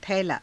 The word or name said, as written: thailaa